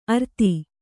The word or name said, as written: ಅರ್ತಿ